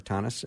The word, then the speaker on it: Thoennes: It's Thoennes, like Thomas with an N, Thoennes.